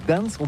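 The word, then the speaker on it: Gdańsk: Oui, c'est pas très facile pour des étrangers, mais remarque bien que la langue polonaise, c'est la langue où on prononce chaque lettre qu'on écrit. C'est pourquoi on dit Gdansk.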